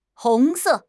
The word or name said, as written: hong2 se4